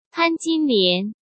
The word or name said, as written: Pan Jinlian